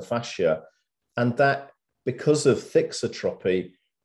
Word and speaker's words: thixotropy: Yeah, well, the actual effect is called, this is a tricky one to pronounce, it's called thixotropy.